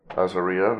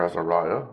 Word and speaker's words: Azariah: Azariah, or Azariah, as Americans say, who's also known by the name Uzziah or Uzziah, is the same king who died in Isaiah 6.